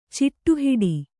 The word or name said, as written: ಚಿಟ್ಟುಹಿಡಿ